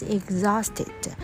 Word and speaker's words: exhausted: 传统上呢 prisoner 是指囚犯，这个囚犯就会被 is kept 被关在 in a prison, P-R-I-S-O-N, 然后当作是一种 punishment, 一种处罚。但是这里呢他就是因为他困住他了、赖着不走，所以呢仿佛就是这个 troll 的囚犯。好，这个 troll 他怎么样呢？ He carried it everywhere until he was exhausted. Who was exhausted? Sinbad. Sinbad was very tired means exhausted. E-X-H-A-U-S-T 然后加上 E-D Exhausted 注意 H 没有发音。好， Exhausted means very tired，那他就非常的疲劳。然后因为他带着这个山精这个 troll 到处去。